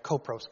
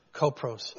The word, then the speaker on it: kopros: kopros, K-O-P-R-O-S, kopros, which is the Greek word for dung.